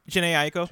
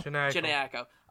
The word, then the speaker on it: Jhené Aiko: Jhené Aiko. – Jhené Aiko.